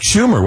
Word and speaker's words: Schumer: I believe that was Chuck Schumer, was it not? – Yes. Yeah. His, yeah. The senator's name rhymes with rumor.